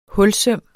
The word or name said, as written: hulsøm